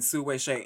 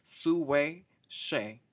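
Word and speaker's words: Hsieh Su-wei: Okay, so the winner of that match faces the winner of what should be an absolutely crazy first round between Su Wei Shea. And before y'all drag me for this pronunciation, I did check the website, and that's what Sis said her name was. Su Wei Shea.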